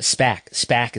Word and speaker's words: SPAC: From Bloomberg, Berrios and Wilson, SBAC may acquire Bolero. Bolero Corp, a operator of bowling centers at SPAC is the proper pronunciation.